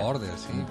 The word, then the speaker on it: Gaarder: Gaarder, sí. – Jostein Gaarder.